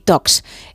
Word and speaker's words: Talks: Son charlas de tecnología, por eso Tech y Talks. Es que no se pronuncia la L, queremos ir de casi nativos, ¿no? Hay que pronunciarlo bien.